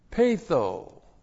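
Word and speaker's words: peitho: Patho. P-E-I-T-H long O.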